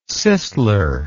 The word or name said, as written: Sisler